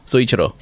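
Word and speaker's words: Soichiro: Suichiro.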